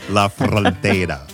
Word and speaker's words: La Frontera: I like how you did La Frontera. You were doing a little bit of rolling of the tongue. La Frontera. Very. – I feel like it's you have to respect the proper way to say it if you can, La but frontera.